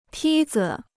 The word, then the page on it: 梯子: tī zi